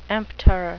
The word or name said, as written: emptor